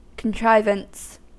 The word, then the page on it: contrivance: American English